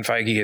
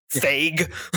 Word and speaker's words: Feige: I did not realize that was how to pronounce his name until just the other day. I heard Tom Holland say it in an interview. I'm like, what? It's not Fague.